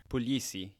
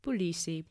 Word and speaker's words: Puglisi: And as part of that episode, they talked to Christian Puglisi. Is that how you pronounce it? – Puglisi, yeah. – Puglisi.